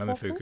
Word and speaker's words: Momofuku: Is it Fuku? Am I pronouncing it Momofuku? – Correctly? Yeah. Momofuku. Yeah.